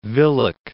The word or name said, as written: Willoch